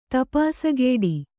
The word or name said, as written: ತಪಾಸಗೆಡು